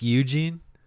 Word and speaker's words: Eugen: Not like Eugene? – I think it's like Eugene. Eugene. It's spelled E-U-G-E-N for the listener out there. I think it's Eugen.